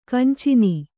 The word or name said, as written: ಕಾಂಚನಿ